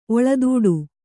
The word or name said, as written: ಒಳತೋಟಿ